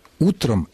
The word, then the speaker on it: утро: Само же существительное «утро» сохраняет ударение на корне во всех формах, в единственном и во множественном числе, если оно употребляется без предлога.